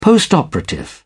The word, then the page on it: postoperative: British English